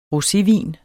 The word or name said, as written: rosévin